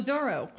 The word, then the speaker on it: Zorro: Near next to the poster of Zarlo. – Oh, Zorro.